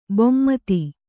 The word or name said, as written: ಭೂಮಿತಿ